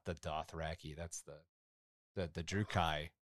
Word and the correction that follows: Drukhari: The Drukhai? Drukhai? How do you say Dark Eldar? – Drukhari.